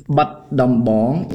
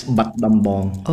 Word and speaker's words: Battambang: And how do I pronounce the first place you talked about again? – It's Battambang in Khmer. It's written Battambang, but the Khmer is Battambang.